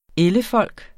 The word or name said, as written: ellefolk